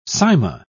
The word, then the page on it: cyma: British English